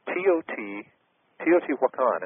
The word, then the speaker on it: Teotihuacan: But Teotihuacan is how it's pronounced.